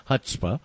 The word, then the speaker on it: chutzpah: C-H-U-T-Z-A-P-H or something? Chutzpah is how it's pronounced.